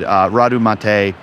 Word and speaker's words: Radu Matei: Radu Mate.